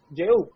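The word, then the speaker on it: Jehú: ¿Cómo se dice Jehu en español? Ehu, yo quiero decir Ehu. Ehu. – Jehu. – Ehu, ok.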